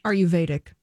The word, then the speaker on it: Ayurvedic: A-Y-U-R-V-E-D-I-C. – Ayurvedic.